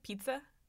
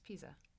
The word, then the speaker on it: Pisa: Is it Pisa? Pizza? – No, it's Pisa.